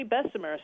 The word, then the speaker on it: Bessemer: So who was Henry Bessemer? Is that how we say his name? – Yeah, Henry Bessemer.